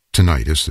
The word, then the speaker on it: tinnitus: And lastly, I do want to mention as a contributor to hearing loss, tinnitus, or tinnitus as some of you may pronounce it.